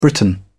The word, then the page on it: britain: British English